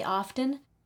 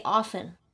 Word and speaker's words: often: And I've heard many people say often, but I say often. – Often. This with like a silent T? – Right.